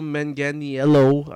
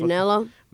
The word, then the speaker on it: Manganiello: Then there's Joe Manganiello. I don't know how to pronounce it. Manganiello.